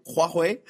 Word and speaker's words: Huawei: Le 1er décembre dernier, le Canada a arrêté Mme Meng, une dirigeante de la compagnie de télécommunications Huawei. Je l'ai bien prononcé. Oui, oui. Huawei.